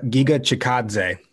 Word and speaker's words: Giga Chikadze: Giga, right? That's how you say it, Giga? – Giga Chikadze.